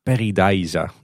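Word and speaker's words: Pairi Daiza: Maar het zijn echt de Nederlandstalige Belgen die zeggen Peridiza. Dus dat is hoe je het blijkbaar echt moet uitspreken.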